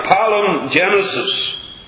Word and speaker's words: palingenesis: If I give the word in the Greek an anglicized pronunciation, perhaps that will help. And we'll say a palingenesis.